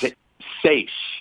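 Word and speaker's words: seiche: Is it Seachy? Seach? How do you say this word again? What is this? – Seish.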